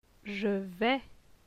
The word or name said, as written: Je vais